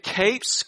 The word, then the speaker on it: Capes: My name is David Capes. Capes is spelled like Batman and Robin wear capes. So you kind of remember it that way.